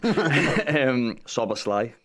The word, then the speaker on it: Szoboszlai: Szoboszlai, done well.